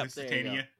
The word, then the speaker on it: Lusitania: We have our own Steve here, that doesn't know how to pronounce the Lusitania? – Is that what it is? – Yeah. It's a ship. – Lusitania.